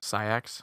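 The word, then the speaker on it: Saïx: How do you pronounce this again? – Syax. – Syax, okay.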